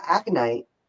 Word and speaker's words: aconite: For the aconite, am I saying that right? – Mm-hmm.